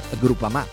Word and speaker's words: Groupama: Ah no, si pronuncia Groupama.